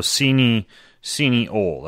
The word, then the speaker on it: cineole: So cineole. That's how you pronounce that? Cineole? – Yeah. – C-I-N-E-O-L-E.